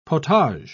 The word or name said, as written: potage